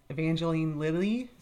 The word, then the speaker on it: Evangeline Lilly: And this has Evangeline Lilly.